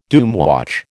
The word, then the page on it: doomwatch: American English